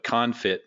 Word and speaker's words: confit: I have no idea what confit means, but you helped me pronounce it confit.